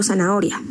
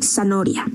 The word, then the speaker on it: zanahoria: Puede ser por faltas ortográficas, como por ejemplo zanahoria. La pronuncian zanoria, ¿no?